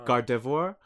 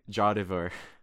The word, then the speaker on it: Gardevoir: Yeah, or Gardevoir. I used to say Jardevoir.